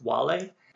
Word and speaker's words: Wale: Another young man was named Wale, spelled like whale, but pronounced Wale.